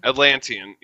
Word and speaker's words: Atlantean: How do you say that? Oh, Antlesian, like origin? – Atlantean? Like a Atlantean? – Atlantean, yeah.